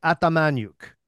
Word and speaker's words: Atamanuik: So Atamanuik is how you say it. Atamanuik. – Okay. – Now that's Canadianized. So it's Ukrainian. So it should be Atamanuik. That's the way it should be pronounced.